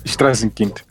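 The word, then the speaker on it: Straßenkind: Strassenkind.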